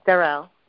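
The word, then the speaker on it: Darrelle: Yes, Darrell. – Yep, okay. – Sister Darrell, can you spell that for me? Is it D? – Sure. D as in David, A-R-R-E-L-L-E.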